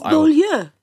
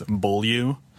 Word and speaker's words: Beaulieu: A lot of my family members just use like the Anglophone pronunciation, which is Beaulieu.